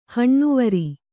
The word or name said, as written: ಹಣ್ಣುವರಿ